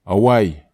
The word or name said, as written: awai